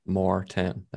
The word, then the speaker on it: Maurten: Morton, if you've seen it, you have to have seen it because they're just more 10. Maurten. That's how they spell it. M-A-U-R-T-E-N. – Maurten. – Yeah. I don't know. You can say it your way, but that's how it's spelled.